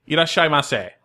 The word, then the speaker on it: itadakimasu: Can you say properly? – Absolutely. Itadakimasu. – Okay. – So that's. Yeah. – It's like five syllables.